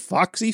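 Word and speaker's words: Väksyvä: The name of the town is spelled V A with an umlaut. With an umlaut, K S Y V A Foxy. – It's Foxy, Finland.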